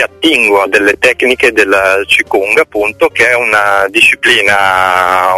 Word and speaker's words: Qigong: Sul corpo, e ti ispiri alle tecniche di Qigong, si pronuncia così? – Sì, esatto, ti sento comunque sempre molto lontana, comunque penso di avere capito, e intanto parlo così magari vi do modo di risolvere il problema.